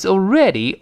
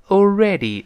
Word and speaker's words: already: 那呃，但第二个人，第这个这个这个第一个人说了，他说但是已经非常接近了，九十八度了，马上就一百了。那 but it's already awfully close。他说现在已经非常接近了。首先注意这个已经这个词，注意发音，叫 already。 already 字母 r 要勾舌，那最后那个 y 呢，在末尾发 e，不要发 a，所以不是 already，是 already。